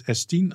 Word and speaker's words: S10: En nog even tot slot, hoe gaan we je naam uitspreken? Is het Stien of S10? – Eh, S10. – Oké, dat was het interview eh, met S10, opgenomen in Tuschinski.